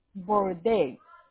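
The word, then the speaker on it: Bourdase: and the candidates were Dion Bordes. – I'm sorry, I didn't understand you. – Of course. My apologies. The candidates were Dion Bordes.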